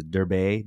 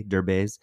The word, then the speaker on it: Derbez: I always say his last name wrong, but it's Derbe, Derbez.